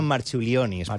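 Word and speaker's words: Marčiulionis: Jo crec que li diuen Marchulionis, però... Perquè que aquesta C es pronuncia com una TX.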